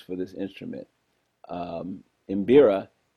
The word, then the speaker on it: Mbira: The instrument is called Mbira, and I'll spell it M-B-I-R-A. So you can say Mbira.